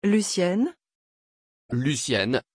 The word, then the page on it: Lucyenne: French